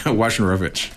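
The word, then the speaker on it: Wojnarowicz: Wajnarowicz.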